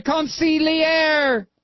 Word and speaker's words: consigliere: Okay, so I didn't pronounce it right. Consigliere. The consigliere. Well, you try to pronounce it. It's hard. It's like French-Italian. It's very difficult.